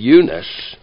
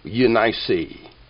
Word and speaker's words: Eunice: Now, it looks like Eunice in our Bible, but in my self-pronouncing Bible, instead of Eunice, it tells us to pronounce this Eunice.